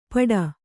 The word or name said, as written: ಪಡ